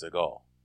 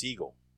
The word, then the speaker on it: Seagal: His name is not pronounced Seagal. – Oh. – It's really pronounced Seagal.